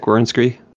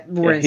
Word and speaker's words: Werenski: So, yeah, Zach Werenski. – Yeah, Werenski.